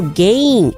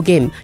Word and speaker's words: gain: 然后 gain is spelled G-A-I-N，一样是长母音 gain、而且后面是 N 不是 M、所以呢你的发音如果不对的话，很多小朋友都会故意说 No pain no gain、 No pain no game，就是没有笔就没有游戏、不是这个意思、所以你的发音如果不对很容易被人家误会。